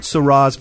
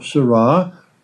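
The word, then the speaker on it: Sarah: We are continuing discussion of this very rich book, The Day Is Now Far Spent, by Robert Cardinal Seurat. Rhymes with Arab because he's a French speaker. And in French, you always accent the last syllable, like Es Noir.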